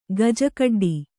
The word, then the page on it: ಗಜಕಡ್ಡಿ: Kannada